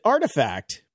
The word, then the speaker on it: RTFKT: They pronounce it artifact, but when I read it, I just read rat-fucked.